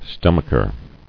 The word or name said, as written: Stomacher